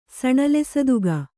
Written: ಸಣಲೆಸದುಗ

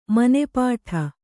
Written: ಮನೆಪಾಠ

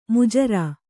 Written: ಮಜೂರ

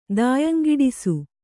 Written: ದಾಯಂಗಿಡಿಸು

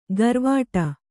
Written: ಗರ್ವಾಟ

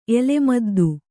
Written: ಎಲೆಮದ್ದು

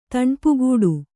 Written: ತಣ್ಪುಗೂಡು

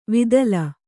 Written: ವಿತಲ

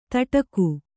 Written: ದಡಿಕೆ

